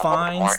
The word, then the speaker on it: Fiennes: Ralph Fiennes, or however you say his name. – Ralph Fiennes, thank you very much.